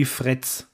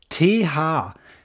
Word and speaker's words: Threads: Das freut sich der Deutsche, er kann es nämlich nicht richtig aussprechen, und dann klingt es nämlich so wie Fritz. Ne? Fritz oder Fretz, ne? Fred Feuerstein. Es heißt aber Threads.